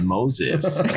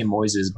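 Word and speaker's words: Moisés: But everybody else, like, they'd be like, I'm like, yeah, man, you can say Moises, but for white people like you, it's just Moses, you know, just keep it simple, bro.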